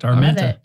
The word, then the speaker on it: Armenta: And let me make sure I'm saying your name right. Armenta? – That's correct. Armenta.